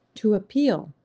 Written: To appeal

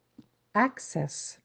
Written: access

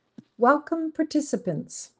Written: welcome participant

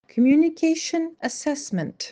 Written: communications assesment

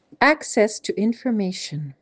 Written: Access to information

